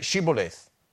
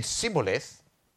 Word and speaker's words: Shibboleth: If he replied no, they said, "All right, say Shibboleth." If he said Sibboleth, because he couldn't pronounce the word correctly, they seized him and killed him at the fords of the Jordan.